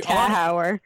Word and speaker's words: Tachauer: I don't know how to say her last name, so she's going to help me. We have Allana. How are you? – Tachauer. – Tachauer? – Yes, the C silent.